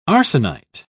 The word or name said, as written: arsenite